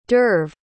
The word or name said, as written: d'oeuvre